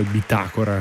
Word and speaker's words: bitácora: Bitácora capillar, intanto mi correggono la pronuncia, Bitácora, avevo detto Bitácora.